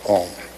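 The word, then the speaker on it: AUM: Explain om.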